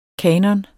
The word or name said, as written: kanoner